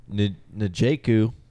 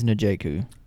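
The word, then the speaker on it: Njoku: N'Joku. How do you say his name? – I think it's N'Joku. – N'Joku? – N'Joku.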